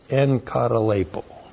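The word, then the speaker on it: N, katalepo (ἐγκαταλείπω): E, and you, when you have two gutturals together, this is pronounced like an N. N, ka, ta, l, e, I, p, long O. N, katalepo.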